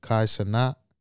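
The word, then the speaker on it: Kai Cenat: His name is, I don't know if I'm saying it right, Kai Sanat. – Yeah, that's how you say it.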